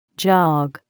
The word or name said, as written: jog